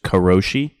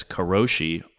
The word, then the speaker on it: karoshi: It's just karoshi, K-A-R-O-S-H-I.